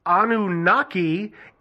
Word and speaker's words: Anunnaki: They are the deities known as the, I'm going to butcher this, Anunnaki in the Babylonian creation myth.